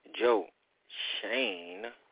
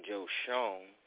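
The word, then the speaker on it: Joe Schoen: I called him Joe Sean in previous episodes, didn't know how to pronounce his name correctly, but now that he is a New York football giant general manager, please put some respect on his name and get it right.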